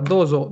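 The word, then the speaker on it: dozo (どうぞ): Watashi Honga suki K-pop ga J-pop ga Remon pai ga Doso. Yoroshiku Ya, eh, todo súper bien eh, o trata de pronunciar un poquito más la Z, dozo, dozo, dozo, dozo, yoroshiku, yoroshi.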